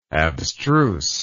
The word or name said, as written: abstruseness